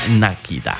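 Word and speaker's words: Nikita: Esto se llama Nakita, diría mi buena amiga Liz. Me dijo, profe, eso se pronuncia Nakita.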